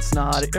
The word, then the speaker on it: Snod: My name is Ryan Snod. It rhymes with odd, and you're listening and watching the Rhymes of the Odd podcast.